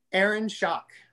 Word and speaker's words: Aaron Schock: But before that, is it Aaron Schenck? – Shock? – Aaron Schock? – Aaron Schock. – Yes. – Congressman Aaron Schock, I think from Illinois.